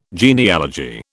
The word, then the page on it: genealogy: British English